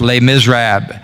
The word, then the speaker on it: Les Misérables: In French, it's pronounced Les Misérables.